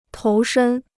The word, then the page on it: 投身: tóu shēn